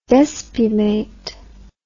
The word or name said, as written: despumate